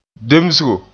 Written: demsgo

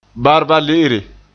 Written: barbale'ere

